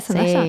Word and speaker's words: Zendaya: En cambio, ves a, no sé, Zendaya, ¿se dice así su nombre? Zendaya. – Sí.